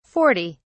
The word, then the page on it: 40: British English